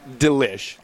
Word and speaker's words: Delish: Are you sure it's not Delish? – Delish is how we pronounce it.